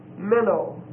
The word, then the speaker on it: meno: You spell minnow, M-E-N-O. And it's a long O. Minnow.